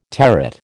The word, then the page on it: territ: American English